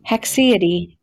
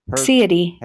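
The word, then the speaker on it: haecceity: Hexiety. – And it comes from mid-Latin of the 17th century. Hexiety. Welcome to the show. You were on the potluck. – And the hexiety.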